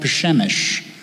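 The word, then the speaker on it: Przemyśl: Incidentally, the place where they crossed over the border was a small village called Pshemish. You can't spell it, and you probably can't pronounce it too well either. I grew up pronouncing it because that's where the Shiffmans came from, which was kind of weird because when I was there, I met the mayor of Pshemish.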